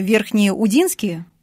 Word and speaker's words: Верхнеудинск: Кстати, Антон Павлович Чехов, когда ехал на Сахалин, остановился в Верхнеудинске. Удинский, да, Верхнеудинский, правильно я, да, ударение? – И, да, все верно, Верхнеудинск.